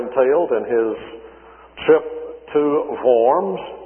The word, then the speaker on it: Worms: He appeared before Charles V and the electors at the Diet of, well, we would pronounce it Worms, the Diet of Worms, some Diet, wouldn't it be? But really, the Germans pronounce it Worms, so that helps us out a little bit.